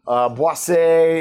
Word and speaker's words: boisé: Boisé is another form. I said it right this time.